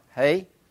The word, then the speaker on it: He: Is that he? Is that the proper pronunciation of that? Hey? All right, hey.